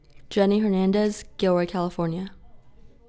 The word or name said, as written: Jennie Hernandez